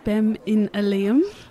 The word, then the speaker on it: spem in alium: And is it said, is it pronounced spem in alium? Is that how this it's said? I think so, yeah. It's Latin.